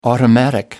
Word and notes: automatic: American English

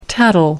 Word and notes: tattle: American English

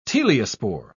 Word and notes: teliospore: British English

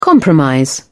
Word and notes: compromise: British English

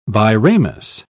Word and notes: biramous: British English

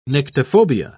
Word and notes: nyctophobia: British English

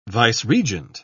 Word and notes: vice-regent: British English

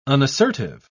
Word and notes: unassertive: British English

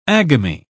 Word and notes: agamy: British English

